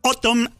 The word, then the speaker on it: Atum: que se pronuncia como decir otoño en inglés, pero se escribe Atum, y está dividida en tres partes. En la casa del rock naciente vamos a escuchar Autumn Act 1 con el tema Embracer,